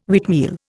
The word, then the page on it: wheatmeal: Indian